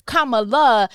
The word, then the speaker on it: Kamala: Kamala, make sure you say her name right, Kamala Devi Harris is going to be the vice president of the United States.